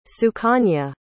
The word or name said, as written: sukanya